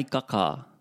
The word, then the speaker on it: kakā: Kai kakā. – Karawe, karawe. Note the stress on the last A. The macron on the last A. Kakā, kakā.